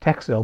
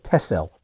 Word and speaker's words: Texel: Yeah, first of all, everyone in Russia and Georgia pronounces it Texel, but actually the Dutch call it Tessel for some reason. They say the X is like an SS sound.